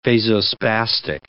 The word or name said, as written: vasospastic